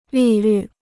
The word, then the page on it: 利率: lì lǜ